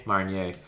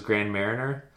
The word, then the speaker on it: Marnier: Marnier. I thought for years it was Grand Mariner.